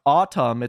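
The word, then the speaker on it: ATUM: Here, they announced a new album called, I believe it's pronounced Autumn. It's A-T-U-M.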